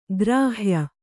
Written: ಗೃಹ್ಯ

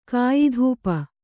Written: ಕಾಯಿಧೂಪ

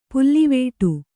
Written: ಪುಲ್ಲಿವೆಟು

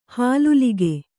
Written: ಹಾಲುಲಿಗೆ

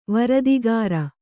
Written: ವರದಿಗಾರ

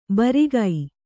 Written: ಭಾರಿಕ

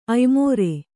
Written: ಆಯಮೇರ

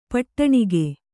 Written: ಪಟ್ಟಣಿಗೆ

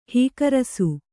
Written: ಹೀಕರಸು

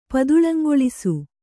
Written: ಪದುಳಂಗೊಳಿಸು

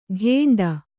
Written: ಘೇಂಡ

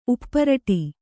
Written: ಉಪ್ಪರಟಿ

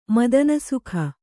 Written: ಮದನಸುಖ

